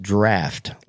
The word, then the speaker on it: draught: A preacher came and corrected me the way I pronounced, I pronounced it draught, D-R-A-U-G-H-T. And he said that it was pronounced draft, like laugh, L-A-U-G-H. – Uh-huh. – Draft, D-R-A-U-G-H-T.